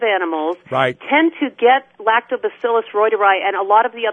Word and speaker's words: reuteri: First of all, the little mice did not have, Lactobacillus rhodii, is that what it was called? – It's pronounced, reuteri or reuteri, yeah.